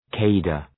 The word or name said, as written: cadre